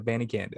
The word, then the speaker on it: Abanikanda: Yeah, it's Israel Abanikanda. Yep, that's how you say it, Abanikanda.